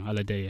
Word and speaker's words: Alade: You've also got Yemi Alade, is that how you say it, or Alade? – Yemi Alade, yeah.